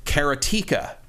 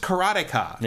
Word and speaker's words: Karateka: but the announcer said Karatika. No, and I'm like, is that how you're supposed to pronounce it? Because I, I, I, don't think that's I don't think that's right.